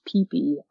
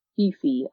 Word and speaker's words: Phi Phi: And it's spelled P-H-I-P-H-I, but it's actually pronounced Peepy Island, not Fifi Island.